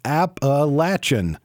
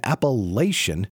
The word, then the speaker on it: Appalachian: At Appalachian State, Mountaineers have a preference in the pronunciation of this word. We say it, as I previously said it, Appalachian. According to the online Merriam-Webster Dictionary and several others, both Appalachian and Appalachian, an alternative pronunciation, are acceptable.